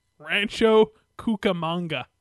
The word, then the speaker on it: Rancho Cucamonga: Rancho Cucamonga.